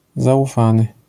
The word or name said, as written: zaufany